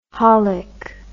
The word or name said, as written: holic